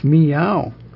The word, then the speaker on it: Miao: Miao, it's Miao. If we put it in English... Miao. Miao. There's no accent, or no pitch indication on the second syllable, which is a diphthong, O-U, in English. That's unusual.